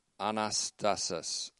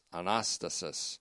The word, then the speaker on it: anastasis: And its noun counterpart, anastasis, anastasis, that's A N A S T A S I S.